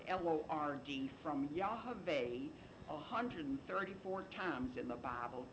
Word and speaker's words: Yahweh: And it's pronounced Yahweh. It is not Jehovah. It is not Yahweh. That's incorrect. Now, that's the correct way.